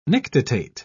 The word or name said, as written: nictitate